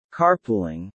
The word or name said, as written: carpooling